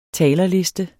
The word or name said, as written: talerliste